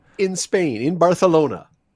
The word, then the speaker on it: Barcelona: A celebrity Ben story of the day offering comes to us from Barcelona, which is the proper way to say it. Not Barcelona, it's Barcelona. Just for those wondering, and my wife who always mocks me for that, but that's how you say it, I'm told. I don't speak Spanish, but I'm told it's Barcelona.